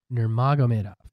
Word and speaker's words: Nurmagomedov: Khabib Nurmagomedov. Did I say that right? – Nurmagomedov. – Khabib Nurmagomedov.